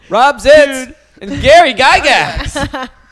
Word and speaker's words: Gygax: Gary Gygax. Did I say it right? – Yeah, Gary Gygax.